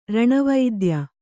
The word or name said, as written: ರಣವೈದ್ಯ